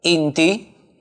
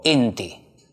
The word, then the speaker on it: inti: Se escribe inti con i y se pronuncia inti o inti.